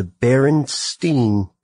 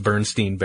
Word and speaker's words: Berenstain: Berenstain Bears, or should I say the Berenstain Bears? – I thought it was Bernstein. When I grew up, it was Berenstain Bears. – Or Berenstain Bears.